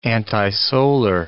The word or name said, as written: antisolar